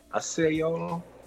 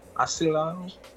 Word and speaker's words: Osceola: Okay, now he's a Spanish dude, and he's from um, Arcelo County, Florida. Did I say that right? Osceola, Osceola.